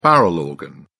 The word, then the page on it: barrel organ: British English